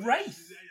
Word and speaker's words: Wraith: The Wrath. – What's The Wrath? – So, this was Charlie Sheen. And The Wraith. – The Wraith, sorry. – That's how we pronounce it.